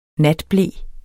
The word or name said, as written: natble